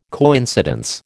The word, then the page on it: coincidence: American English